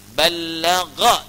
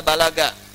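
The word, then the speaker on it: Ballagha (بلّغ): Ballagha dibacanya, bukan bahasa Sunda balaga, ya. Ballagha yuballighu. Sesuatu yang menyampaikan dengan jelas.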